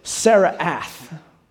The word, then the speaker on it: Tsaraath: The name of the condition is this, Saraath. It's spelled this way. You can see it up on the screen here, Saraath, T-S-A-R-A-A-T-H.